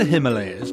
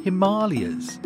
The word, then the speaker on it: Himalayas: Well, you say the Himalayas, but apparently locally it is pronounced Himalayas.